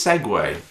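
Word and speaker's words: segue: Segu? – Oh, is it pronounced seg or segway or sugi? – I think it's segway.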